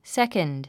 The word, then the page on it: second: sékənd; セコンド